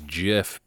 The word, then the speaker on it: GIF: Who insists on pronouncing GIF as JIF because the creator says that's how you pronounce it,